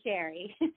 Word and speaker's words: Cherie: And I love the French pronunciation of my name, but really I just go by Cherie.